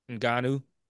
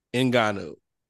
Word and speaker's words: Ngannou: How do you pronounce Francis' last name? – Ngannou. – Ngannou. – Yep.